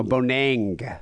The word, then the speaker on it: Bonet: Is that the French pronunciation of boner? Bonet? – Bonang. – Oh, Bonang. Okay.